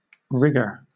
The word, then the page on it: rigger: British English